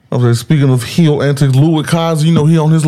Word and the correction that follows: Ludwig Kaiser: He ain't say it right, bro. – I'm sorry. Look, I'm not Sam Irvin, but I'm going to give my... – Ludwig Kaiser. – Like, I'll go... like, Ludwig Kaiser.